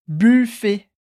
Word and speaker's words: buffet: Buffet. Buffet. De novo, a gente escreve um T no final da palavra, mas ele não é pronunciado.